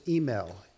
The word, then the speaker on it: Himmel: But Thomas Himmel, am I saying that right? – It's email. – Email. – Almost like email, but email.